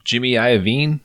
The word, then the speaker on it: Jimmy Iovine: And Jimmy Iovine, is that how you say his name? – Yeah.